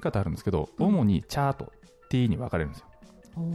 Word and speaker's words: 茶: そうなんですよ、発音の違い、うんうん、で中国のどの地域から伝わったかが分かるんですよ、はい、で広東省ではお茶のことチャーと発音するんですね。 – うーん、で福建省ではテーと発音するんですよ、